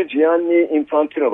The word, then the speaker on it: Gianni Infantino: Karikatürde FIFA Başkanı Gianni Infantino var.